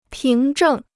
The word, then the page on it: 凭证: píng zhèng